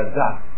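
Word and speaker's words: Daat (דעת): Pero ustedes ven que dice Daat, hay dos A, porque debajo de la Dalet hay una rayita, que se acuerdan que los signos vocálicos se agregan a las letras, entonces se pronuncia Daat.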